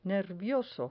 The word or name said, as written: nervioso